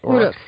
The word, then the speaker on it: Uruks: Uruks, yeah.